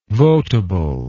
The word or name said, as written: votable